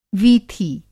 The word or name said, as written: वीथी